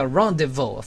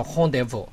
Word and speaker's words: rendezvous: 最后我们来看一个例句，这个字超酷的，在英文呢就叫 rendezvous，法语就叫 rendezvous。啊，它字尾就不发音了。我们先看例句吧。中文我举了一个例句，他说：“ 我们下周五约会不是吗？” 这个例句不难，但我们来看法语的三个例句。他约了牙医呢。 – Il a rendez-vous chez le dentiste.